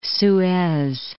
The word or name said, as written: Suez